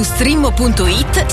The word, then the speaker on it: Streammo: Vai su Strimmo.it, si scrive Streammo o scarica l'app.